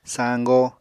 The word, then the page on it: sañgo: Apatani